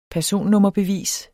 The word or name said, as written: personnummerbevis